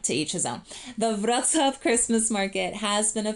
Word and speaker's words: Wrocław: Wrocław, we pronounce it as Wrocław, is located in southwestern Poland and is one of the largest cities in the historical region of Silesia.